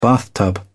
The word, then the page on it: bathtub: British English